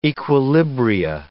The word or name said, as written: equilibria